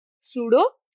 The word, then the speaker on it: pseudo: बल्कि यहाँ पर यहाँ पे जो पी है ना बच्चों वो साइलेंट है ना, हम सूडो नहीं कहते, इट विल बी अ रॉन्ग प्रोनाउंसिएशन। हम क्या कहेंगे, सूडो। पी जो है उसका प्रोनाउंसिएशन नहीं आएगा यहाँ पे, ओके।